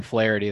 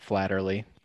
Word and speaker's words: Flaherty: And that was Jack Flaherty that Mike mentioned, not Flaherty or whatever.